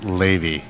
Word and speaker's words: Levi: It's actually pronounced Levi. – Levi. – It's okay. It's the Yiddish pronunciation of Levi. Levi Fried from Long Beach, California, formerly doctor, scientist, physician scientist, and Wait.